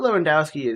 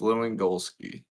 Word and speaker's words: Lewandowski: I mean, dude is literally, Robert Lewandowski is sitting on 39 goals. – You said his name wrong. It's Lewandowski.